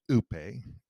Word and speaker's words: upe: Pronounced oope, E-W-E-P-A-Y, spelled U-P-E.